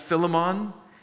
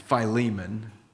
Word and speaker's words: Philemon: Now, I might pronounce that Philemon, but that's just my dyslexia. It's Philemon.